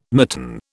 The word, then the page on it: mitten: British English